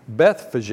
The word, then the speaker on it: Bethphage: When they had approached Jerusalem and had come to Bethphage, I struggled with that. Bethphage is the way that's pronounced.